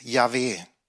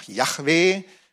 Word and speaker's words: Yahweh: Die üblichste die unter den Gelehrten so am meisten anerkannte, ist Yahweh. Manche sagen auch Yahweh.